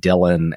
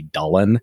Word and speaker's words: Dylan: He did actually choose the name Dylan from this kind of ancient Welsh text, like from the Middle Ages, although the family always pronounced it Dylan and not the Welsh way, which would apparently be Dullen.